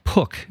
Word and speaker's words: Pook: It's spelled Pook, but I guess it's pronounced like book. So Jocelyn Pook is what we're going to go with.